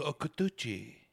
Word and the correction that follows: Kikuchi: It's a T or a K? – It's a K. – Kikuchi.